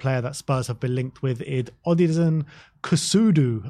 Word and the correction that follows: Kossounou: Uh, I'm not sure if I've uh, Kossounou. Kossounou.